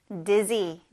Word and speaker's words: dizzy: I feel dizzy 라고 하면 됩니다. 여기에 Z 발음, dizzy예요.